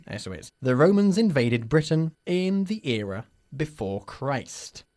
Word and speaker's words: Britain: ¿Cómo pronunciamos nosotros Britain? – Britain. – Muy bien. Has estado muy atenta, Annie. Decimos Britain y no Britain.